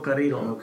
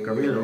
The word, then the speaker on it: Carrillo: Humberto, uh, how, do, how do you say his name? Humberto Carrillo. Okay, Carrillo. Yeah, yeah, yeah. So Carrillo. him.